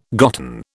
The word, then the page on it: gotten: British English